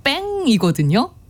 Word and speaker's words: pain: 그게 스펠링이 P-A-I-N이거든요. 그러니까 영어로는 pain, 고통이에요. 근데 프랑스어로 읽으면 뺑이거든요.